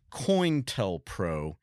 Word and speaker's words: COINTELPRO: So the FBI conducted an activity called COINTELPRO. And you'll hear it pronounced differently depending on who you're talking to. I always heard it called COIN, like a coin, TELPRO.